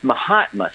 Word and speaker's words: Mahatma: It's Mahatma. So it's my hat and my cane.